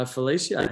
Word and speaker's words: Felicia: And um, yeah, special guest is uh, Felicia.